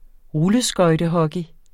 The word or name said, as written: rulleskøjtehockey